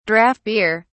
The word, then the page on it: draught beer: British English